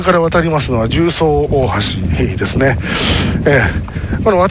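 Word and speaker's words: 十三: 重曹と 13と書いて重で重曹と読むようになったと。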